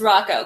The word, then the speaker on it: Rocco: It's Rocco, because it rhymes with taco.